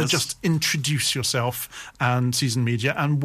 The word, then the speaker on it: SZN Media: But Season Media it's spelt SZN.